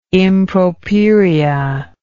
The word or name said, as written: improperia